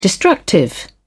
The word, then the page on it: destructive: British English